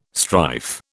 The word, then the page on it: strife: British English